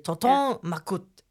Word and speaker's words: Tonton Macoute: Jeg tror, det udtales Tonton Macoute. – Det er også sådan, jeg er kommet frem til det. Tonton Macoute.